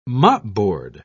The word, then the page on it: mopboard: British English